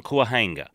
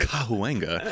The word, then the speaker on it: Cahuenga: It shouldn't be an apartment on Cahuenga. – Yeah. – Ooh. Respect to the pronunciation. – Thank you. – Cahuenga.